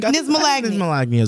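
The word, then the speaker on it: knismolagnia: I feel like the K is silent, like Nick's. Nismalagnia. – Yeah, I think Nismalagnia is good.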